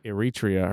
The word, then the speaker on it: Eritrea: Eritrea are the only two countries without vaccines.